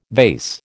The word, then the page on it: vase: American English